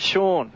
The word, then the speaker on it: Sean: Now, I hope I pronounced your first name correctly, Shane Clearahan. – No, mate, Sean. – Sean. Oh, Sean. No. The S-E-I-N, you pronounce it Sean.